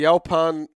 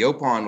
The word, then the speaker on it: Yaupon: So the Yopan, it's pronounced Yopan, right? – It comes from a Catawban word. It means little tree.